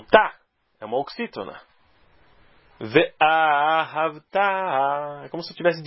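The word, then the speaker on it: Ve'ahavta (וְאָהַבְתָּ): A sílaba tônica, no caso, é o tav, é o final. Ve'ahavta é a oxítona.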